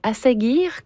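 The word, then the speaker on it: Ásgeir: Moi, je découvre cet artiste Asagir, qu'on prononce.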